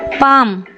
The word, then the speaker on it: psalm: ಪಾಮ್ PSALM ಪಾಮ್.